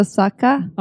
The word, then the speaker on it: Saka: No, it's actually typing error. It's not Shahak. – So it's a K instead of an H. Had a typing error. – Oh, so it's not my fault. – No, it's not your fault. That was me. – Okay, thank you. – How do you say it? – Shahak. It's AKA. So Saka? – Okay.